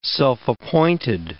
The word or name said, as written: self-appointed